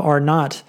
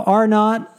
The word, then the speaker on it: Arnott: Rob Arnott. I guess you pronounce it that way, A-R-N-O-T-T.